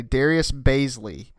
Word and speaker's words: Darius Bazley: Draft picks wise, they signed, and tell me if I pronounce this correctly, Darius Bazley.